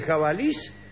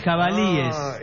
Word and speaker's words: jabalíes: ¿Jabalís o jabalices? – No. De ninguna de las dos maneras, señores. Jabalíes. – ¿Qué? – Jabalíes, el plural de jabalí.